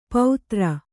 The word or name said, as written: ಪೌತ್ರ